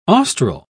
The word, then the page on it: austral: British English